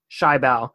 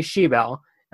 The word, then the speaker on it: Schiebel: It looks like Schiebel, but it is actually Schiebel.